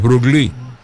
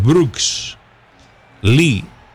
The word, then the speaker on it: Brooks Lee: No es como el actor. Se parece la pronunciación, pero no, no es Bruce Lee, el desaparecido actor. Este es Brooks Lee.